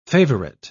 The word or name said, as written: favorite